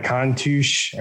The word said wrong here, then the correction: Countach: or the Countach. – Countach. I always say it wrong.